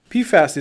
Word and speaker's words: PFAS: PFAS is a good way to pronounce it.